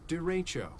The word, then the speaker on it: derecho: Derecho. Here we go, one more time. Derecho. Derecho. Derecho. Der. De. Derecho. Derecho. Derecho. Derecho. Not Derecho. Derecho. Everybody say it together. Derecho. Derecho.